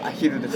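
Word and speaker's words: @HILL: これはですね、名古屋のアットヒルというお読みするんでしょうかね、アヒルなんでしょうか、ちょっとアヒルという、ライブハウス、ね、書き方がアットマークと HILL で、はい、まあ、アットヒルみたいな感じで書くんですけど、うん、読み方はアヒルです。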